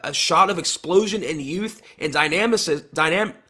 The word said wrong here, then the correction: dynamism: Dynamicism? – I See, think it's, dynamism. I think it's dynamism. Dynamism? Okay. Yeah.